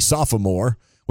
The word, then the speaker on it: sophomore: Texter also pointing out sophomore. I mispronounced. It should be sophomore, which I believe Keith Jackson dominated.